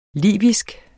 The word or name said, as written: libysk